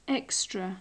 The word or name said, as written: extra